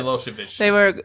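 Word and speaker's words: Milošević: Milosevic.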